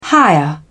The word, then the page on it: higher: British English